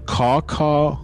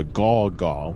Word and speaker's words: caw-caw: The caw-caw, or sometimes pronounced as the gaw-gaw with a G, was a gray, slimy creature that roamed the streets at night.